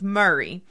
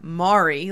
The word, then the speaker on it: Maury: Um, as old Maury County would be the pronunciation of Murray. It is not Mari like Maury Povich.